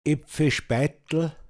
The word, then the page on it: speitln: Pinzgauer Mundart